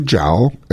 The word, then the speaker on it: Zhao: Botu Zhao and Li Zhao. That's mm-hmm. how you pronounce their names.